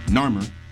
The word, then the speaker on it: Narmer: it was King Namur, I want to pronounce the name right, King Narmer, right,